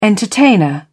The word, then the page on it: entertainer: British English